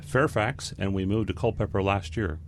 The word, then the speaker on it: Culpeper: Culpeper. It's cull with pepper.